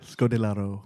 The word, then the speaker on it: Scodelario: A bit over the top at parts, but some wacky characters anchored by great leads in Theo James and Kea Scodelario. – Beautiful. – Is that how you say it? – Scodelario. – Yeah, that will do.